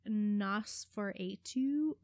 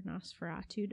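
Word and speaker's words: NOS4A2: Okay, so uh, my next one is Nosferatu or Nosferatu, depending on how you want to pronounce it, by Joe Hill, who, because I call his father Uncle Steve, I feel like I should call him Cousin Joe.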